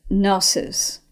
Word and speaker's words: gnosis: Well, the word knowledge in Greek is gnosis, spelled with a G, G N O S I S.